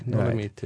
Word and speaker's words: Dolomiti: The, How do you pronounce it? Dolomiti, Dolomiti. Dolomiti. Right.